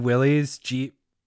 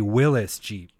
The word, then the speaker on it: Willys: I've been pronouncing Willys Jeep wrong this whole time. It's actually Willys Jeep.